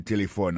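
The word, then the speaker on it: telephone: N'a pas remarqué que l'accent tonique l'a tombé sous premier syllabe là. Si nous besoin de dire bien, c'est téléphone. Alors, ou prononcer ou appuyer plus sous premier syllabe là, qui c'est T.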